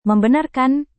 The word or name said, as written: membenarkan